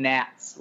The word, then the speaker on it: gnat: Okay, I call them gnats with a silent G.